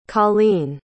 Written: Coline

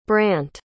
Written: brandt

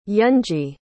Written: eunji

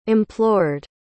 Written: implored

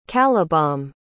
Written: calibome

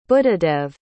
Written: buddhadev

